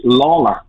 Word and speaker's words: Lalor: My name is actually pronounced Lawler, okay? – Is it really? – It's an Irish name, but most Lawlers spell it with a W, so that's why it would dictate Lawler.